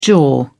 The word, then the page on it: jaw: British English